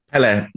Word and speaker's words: Pelle: Is it Pelle or Pelle? – Pelle, with two syllables.